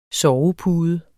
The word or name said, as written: SOVEPUDEN